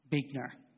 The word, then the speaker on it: Buechner: I love this quote from Buechner. I don't know how to say his name. Buechner. There you go. Spelled funny.